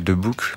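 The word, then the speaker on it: De Boeck: Alors, je crois qu'on le dit un peu à la belge de Book.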